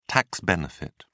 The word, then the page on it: tax benefit: British English